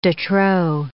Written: de trop

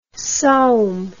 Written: saum

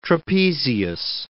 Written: trapezius

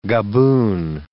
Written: gaboon

